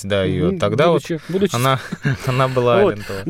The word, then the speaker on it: Алентовой: Думал, Алентовой или Алентовой. Я полез в справочники и нашел там два ударения.